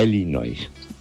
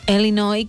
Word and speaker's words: Illinois: Bueno, puede ser, a Illinois, como dice el doctor, que no es, o Illinois, que es una I más tirando a E, cuando se pronuncia, uh-huh.